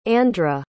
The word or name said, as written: andra